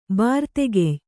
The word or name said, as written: ಬಾರ್ತೆಗೆಯ್